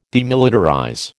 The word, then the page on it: demilitarize: American English